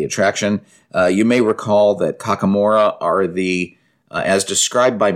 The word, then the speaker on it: Kakamora: Kokomora, am I saying that right? – Kakamora. – I think it's Kakamora.